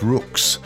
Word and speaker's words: Ruhks: We're going to open today's program with a fairly new recording from the Band of Rooks, and that's spelled R-U-H-K-S, but it's really pronounced R-O-O-K-S.